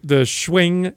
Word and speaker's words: Schwing: I know that the Schwing company now. You have to say it like that.